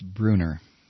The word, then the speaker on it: Brunner: Twenty-one past the hour, and by the way, I've been mispronouncing the Secretary of State of Ohio, Jennifer Bruner. I've been saying Brunner because there's two N's in it, but apparently it's, according to Bill, who emailed me, it's Bruner.